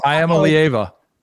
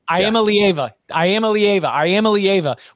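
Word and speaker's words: Iamaleava: Give me the pronunciation. – Arch Manning. – No, I am a Lieva. – I am a Lieva.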